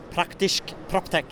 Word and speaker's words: praktisch PropTech: So, if there's anyone in uh, the podcast praktisch PropTech is that the right way to say the podcast in German? – Yes, that's that's the right way.